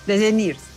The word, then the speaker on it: DeGeneres: DeGeneres.